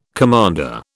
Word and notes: commander: British English

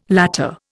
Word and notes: latter: Indian

